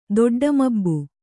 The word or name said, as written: ದೊಡ್ಡಮಬ್ಬು